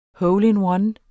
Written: hole-in-one

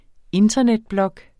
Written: internetblog

